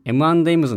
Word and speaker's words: M&M's: ちなみに M&M's の発音は M&M's、ドリトスはドリトスです。使う頻度は低いと思いますが、いい発音練習になるので一緒にやってみましょう。